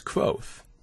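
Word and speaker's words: Kvothe: The very first line of the book that I initially wrote was, "My name is Quoth," pronounced nearly the same as Quoth, like Quoth the Raven. And I'm like, there, that should take care of anyone wondering how it's pronounced.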